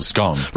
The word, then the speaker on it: scone: 51% pronounce it to rhyme gone. Right. – Oh, scone. – Gone, scone. Yeah. – Yeah. – 42%. This is the weird bit. 42% have come down on the side of it rhyming with bone.